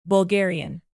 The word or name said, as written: bulgarian